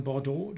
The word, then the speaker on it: Bordeaux: Und dann Bordeaux, das X hinten ist stumm.